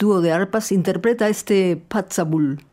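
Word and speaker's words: Puirt a Beul: La misma idea, pero en gaélico, una lengua coexistente con el inglés en Escocia, Patzabul, que se escribe Puit a Beul, melodías de boca.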